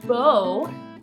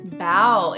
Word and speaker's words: bow: Es igual a la primera que tenemos dos pronunciaciones para esta palabra también. Entonces, bow es el sustantivo y es algo que tienes o te llevas en tu, en tu cabello. Y bow es el verbo y significa bow, verbo, hacer reverencia o inclinarse ante alguien por respeto they bowed before their queen.